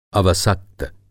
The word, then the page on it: अवसक्त: Hindi